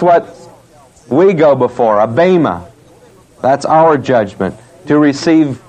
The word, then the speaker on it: Bema: B-E-M-A, B long E-M-A. The Bema.